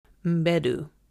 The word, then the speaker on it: Mbedu: Okay, first, it stars Viola Davis, John Boyega, Sheila Ateem, Lashana Lynch, Thuso Badu. Wait, no, y'all be butchering her last name. Let me get it right. Thuso Mbedu.